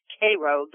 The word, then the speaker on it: Cairo: called Cairo, Illinois, but pronounced Cairo there.